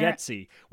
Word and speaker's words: Bargatze: Bargatze. We've been saying it wrong all this time.